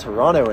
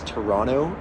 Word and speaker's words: Toronto: We got Toronto in the house. I also learned that it's Toronto. It's not Toronto. It's Toronto. That's how you pronounce that, if that's correct.